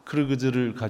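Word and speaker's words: Kyrgyz: 그쪽 사람들에게 접근해서 얘기를 할 때 저희 아내가 배우는 키르기즈, 사실 정확한 발음은 크르그즈입니다. 크르그즈를 가지고서 말씀을 하면 그분들은 대단히 기뻐합니다.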